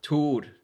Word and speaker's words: Thor: Yeah, but I don't like when people do that either, because it was just a thing when I moved there, people, because it's spelled Thor, T-H-O-R, so yeah. People go, Thor. And then when sometimes people are like, how do you say Thor? And, you know, we roll our R's. – Yeah. – In Norway, so then I go Tor, and then they go Tor.